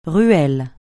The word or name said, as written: ruelle